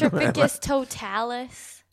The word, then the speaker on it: Petrificus totalus: How do you say a fake word? – Petrificus totalus.